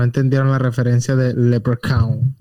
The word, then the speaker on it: leprechaun: Leprechaun. – Leprechaun.